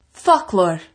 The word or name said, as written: foclóir